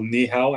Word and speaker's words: Niihau: One of the eight islands is uh, called Niihau. I think that's how you say it, like you know Nihao.